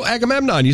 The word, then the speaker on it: Agamemnon: It's Agamemnon from The Odyssey. – Oh, Agamemnon. You said it completely correct.